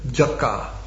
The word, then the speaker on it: yaksha: I've heard Prabhupada pronounce, maybe we can make this a little bit lower. The word yaksha, he called it a jaka.